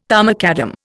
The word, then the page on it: tarmacadam: Indian